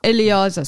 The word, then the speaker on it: Eliezer: Is it Eliezer? – Eliezer.